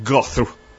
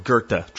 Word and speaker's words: Goethe: G-I-R-T-A would be how you would pronounce it. It looks like Goethe, right? But it's a German word, Goethe. Trust me, that's how you say his name.